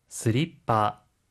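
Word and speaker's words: スリッパ: 스리빠, 스리빠, 슬리퍼고요. 티슈입니다. 혹시 발음 팁 있을까요? – 하이, 플로링은 마룻바닥을 뜻하는 영어 플로어에 ing 발음을 붙인 일본식 영어입니다. 스리퍼란 티슈, 단어 중간에 초금이 들어가는 거에 주의해 주세요.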